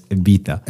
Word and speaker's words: Ibiza: Yeah, but I'm saying that only to say that they all pronounce Ibiza as Ibiza. – Ibiza. – Yeah. – And I was like, I looked at my wife. Damn, we've been saying this wrong all along.